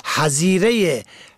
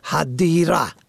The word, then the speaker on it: حظیره: حظیره گونه نوشتاری حدیره، ه دال یا ره، در اصل همون حظیره ح زوات یا ره، یعنی قبر و قبرستان در عربی است که فارسی زبانان در زبان شفاهی همواره ذات آن را دال تلفظ می کنند. حدیره به جای واژه حظیره پیشنهاد میکنیم از همان آرامگاه، آرامگاهی همیشگی یا گور و گورستان فارسی استفاده شود که داریم.